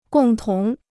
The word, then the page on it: 共同: gòng tóng